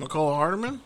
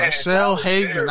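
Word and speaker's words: Mecole Hardman: You mean McCole Hardman? – Yeah, there you go. – Wow. – Sorry. – You butchered his name, dog. – My bad. – He said, man, Marcel Hateman.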